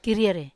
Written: karier

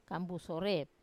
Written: kambu sore

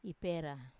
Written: ipera

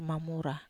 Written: mamura

